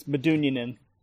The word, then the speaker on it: Medunjanin: Derek Jones was signed late last year um, as a homegrown player, and Harris Metajanine was brought in. Um, I don't know if I'm actually pronounced. I might have. It, is it a soft J? I got it wrong. Yeah, it's it's Medunjanin.